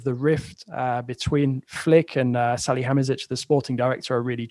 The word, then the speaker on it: Salihamidzic: Salihamidzic.